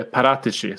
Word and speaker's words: Paratici: För nu, nu, nu märker, eller Paratici som vi har kommit fram till att han ska uttalas. Eh, det verkar varit en seger för honom.